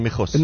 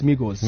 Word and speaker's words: Migos: Migos or Mijos? – No, it's Migos.